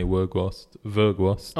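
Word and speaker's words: Weghorst: It's spelled W, but it's pronounced, apparently, Virgost.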